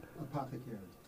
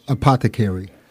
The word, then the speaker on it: Apothecary: Apothecaris? Wait a minute. How do you pronounce that? – Apothecary. – Again? – Apothecary.